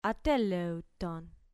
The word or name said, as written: ateleuton